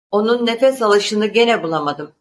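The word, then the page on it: gene: Turkish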